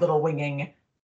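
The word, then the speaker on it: Little Whinging: And I think that I chose to pronounce it Little Winging very specifically.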